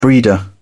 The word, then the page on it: breeder: British English